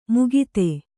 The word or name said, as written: ಮುಗಿತೆ